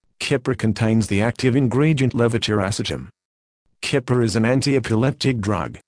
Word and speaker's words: levetiracetam: Active ingredient, levetiracetam, pronounced levetiracetam.